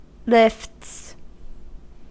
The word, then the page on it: lifts: American English